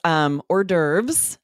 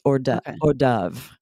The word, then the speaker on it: hors d'oeuvres: It's hors d'oeuvres. That's how we say it in the U.S. Mm-hmm. I looked up the British pronunciation. It's much closer to how you say it in French, hors d'oeuvres. So they say of instead of the herbs like we say.